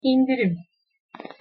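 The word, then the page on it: indirim: Turkish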